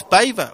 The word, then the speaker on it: Paiva: que ya nos dice que es Paiva, o sea, lo pronuncia así, Renato Paiva.